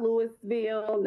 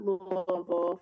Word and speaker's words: Louisville: So not Louisville, not Louisville, but Louisville for all those people out there Louisville. – That say it wrong.